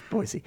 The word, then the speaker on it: Boise: Which is how you say it. – I know. – It's not Boise. – I'm always proud of myself when I say Boise. – It's not Boise. – Yeah, it's Boise.